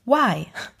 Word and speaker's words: Y: Der Buchstabe Y wird englisch Why, warum ausgesprochen, was auf die teils als charakteristisch für die Generation Y beschriebene Neigung zum Hinterfragen verweisen soll Hinterfragen also.